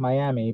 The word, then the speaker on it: Miami: That was in an Indian casino in Miami, spelt like Miami, but pronounced Miami, Oklahoma, in this tiny little place that held like a few hundred people.